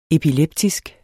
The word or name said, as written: epileptisk